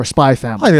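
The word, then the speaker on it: Spy × Family: of where the X is silent, kind of like there's a show it's spelled Hunter X Hunter or like Spy X Family, but you don't pronounce the X. It's just Hunter Hunter or Spy Family.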